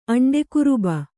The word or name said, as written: ಅಂಡೆಕುರುಬ